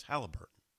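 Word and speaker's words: Haliburton: Yes, Halliburton.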